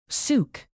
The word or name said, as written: sook